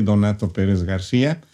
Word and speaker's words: Donato Pérez García: So I want to introduce our guest to you today, and I hope I didn't butcher your name, Dr. Donato Perez Garcia. – No, está bien. Sí, soy Donato Perez Garcia, tercera generación de médicos.